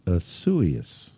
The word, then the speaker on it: Isaias: And the name of this is I-S-A-I-A-S. I-S-A-I-A-S. And from what they have just told me, it is pronounced Isuius. – Oh, I was going to guess Isaias. – That's what I would have said. It's Isuius.